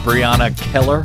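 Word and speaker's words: Brianna Keilar: Well done, Brianna Keller.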